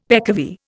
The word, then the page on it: peccavi: Indian